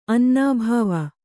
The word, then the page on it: ಅನನುಭವ: ananubhava